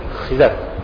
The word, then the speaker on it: خضر: خضر لفظ صحیح لفظ خضر مانتے عوام جو کہتے ہیں خضر خضر خضر بلتا لیکن خضر تو صحیح تلفظ خضر